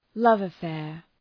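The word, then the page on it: love affair: ˌlʌvəˈfeər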